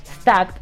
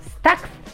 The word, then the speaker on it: stacked: Busca asesoría dentro del vapeo y carita que guiño y luego viene el queridísimo Ernesto Sánchez y le dice, stacked se pronuncia Stack o sea, stacked se pronuncia Stack ¿no? Y stake se pronuncia steak, con el debido respeto, claro. Es decir, no son la misma pronunciación y otra carita que guiña.